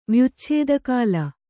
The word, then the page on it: ವ್ಯುಚ್ಛೇದಕಾಲ: kannada